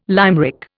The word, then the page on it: limerick: Indian